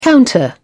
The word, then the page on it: counter: British English